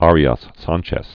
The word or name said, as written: Arias Sanchez